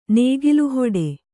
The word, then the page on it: ನೇಗಿಲುಹೊಡೆ: Kannada